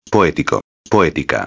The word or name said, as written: poetico-a